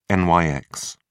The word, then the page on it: Nyx: British English